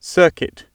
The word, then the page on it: circuit: British English